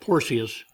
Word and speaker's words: Porcius: Now, my friend Jeb, who studied extensively Latin, said that the C in Latin is always pronounced with a hard K sound. So I was calling it Porcius, but it's Porcius, and for good reason, in fact.